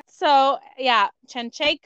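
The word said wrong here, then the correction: Shevchenko: Oh, Chenchenko is how you pronounce it. – Like, Chev, like I'm going to Chev your ass with a knife. Chevchenko.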